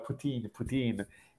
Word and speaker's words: poutine: know the image, like pudding, like the image, so it's all poutine.